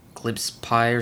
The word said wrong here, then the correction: Gillespie: or some shit. I don't know. – Gillespie? – Gillespie, there we go.